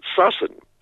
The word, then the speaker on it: Sussan: Given the spelling, it has to be pronounced Sussan.